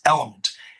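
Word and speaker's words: LMNT: I have yet to try them, but they're called Element, and it's L-L-M-N-T, but it's pronounced Element.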